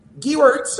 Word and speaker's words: Gewirtz: You can only say it if you say it like that. – Gewirtz. Okay, we'll go with Gewirtz.